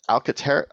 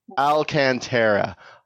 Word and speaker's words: Alcantara: It does have that Alcantara. I always say it wrong. That fuzz. – Alcantara, which I'm totally fine with everywhere else in the vehicle.